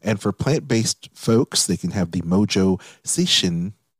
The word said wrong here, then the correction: seitan: Did I say that right? Seishin Bowl? – Seitan. – It's not satanic. It's a seitan bowl.